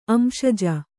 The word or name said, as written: ಅಂಶಜ